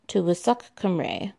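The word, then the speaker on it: Tywysog Cymru: The Welsh pronunciation of this title is Tywysoc Cymru, which translates to the one who leads.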